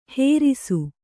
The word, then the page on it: ಹೆರಿಸು: herisu